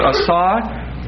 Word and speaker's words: asah: So verse 26, the word "make" is asah, long A, S, long A, H.